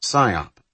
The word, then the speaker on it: SIOP: This acronym, pronounced PSIOP, stood for Single Integrated Operations Plan, and was the master blueprint for dismantling the Soviet Union.